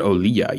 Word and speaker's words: Olija: It is O-L-I-J-A. Oliha, yeah, how I'm spelling it or pronouncing it, but I'm not sure if, I think in the game they pronounce it Oliha even.